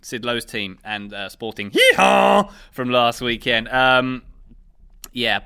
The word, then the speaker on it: Gijón: Sporting Gijón.